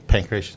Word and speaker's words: Pancratius: Yeah, Pancratius, because he got decapitated.